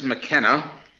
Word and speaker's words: McKenna: All right, my name is James McKenna.